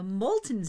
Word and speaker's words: Moulton: All of these wonderful things will be occurring at the Molten Lava Gallery, which is hilarious because we have a Molten Street in Willimantic, and it's not spelled like molten lava, it's spelled M-O-U-L-T-O-N, but it's the Molten Lava Gallery, which is at 31 Molten Court in Willimantic.